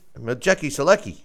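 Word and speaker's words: Maciej Sulęcki: How do you pronounce his name? – Majeki Selecki. – Okay. – Matthew, you know, I've heard people pronounce his first name so many different ways, so, you know, I just call him Selecki.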